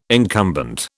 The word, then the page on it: incumbent: British English